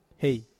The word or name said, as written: hei